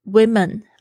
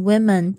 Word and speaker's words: women: 啊，在讲很多男人的时候是 man，好，那女人也是一样哦，woman 是单数的 w o m a n，那复数的在这边呢是 women 啊，那个 o 呢在这边呢会变成 it 的发音，就是小 i 那个发音，women。 It is our duty as men and women to proceed.